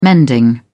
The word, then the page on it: mending: British English